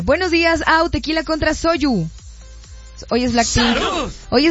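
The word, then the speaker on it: Soyu (소주): ¿se pronuncia Soyu o Soju? Soyu.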